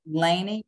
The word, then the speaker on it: Lannie: Lainey, L-A-N-N-I-E, Creeman, K-R-E-M-I-N.